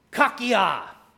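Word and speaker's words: kakia (κακία): He uses an interesting word here that is pronounced as kakia. Say that with me. Kakia, yeah.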